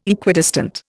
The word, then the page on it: Equidistant: Indian